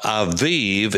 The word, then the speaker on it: Aviv: Now, Aviv is the way it's pronounced in modern Hebrew.